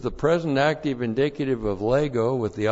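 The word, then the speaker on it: lego (λέγω): L-E-G long O, lego.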